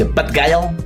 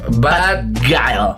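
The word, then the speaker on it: Bad Gyal: ¿Cómo se dice? ¿Bad Guile? – Bad Guile.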